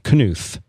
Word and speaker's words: Knuth: So anyway, Knuth is the, if I'm pronouncing his name correctly. – Knuth. – I don't like saying the K.